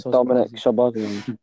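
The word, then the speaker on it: Dominik Szoboszlai: Dominic Szoboszlai.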